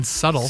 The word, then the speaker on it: subtle: And you don't pronounce the B in subtle.